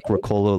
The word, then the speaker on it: Ricola: We have like a thing here that very important, very similar to, you said, Ricola is how you say it? – I think that's okay. Like Ricola with like an American accent, but okay, good to know.